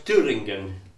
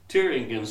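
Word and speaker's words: Thüringen: Now, the heartland of Holda worship, shall we say, in Germany is an area which is spelt Thuringia and pronounced Thuringen. Thuringen, central Germany, is the real heartland.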